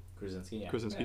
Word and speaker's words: Krasinski: It was like John Krasinski. Is that how you say his name? – Krasinski.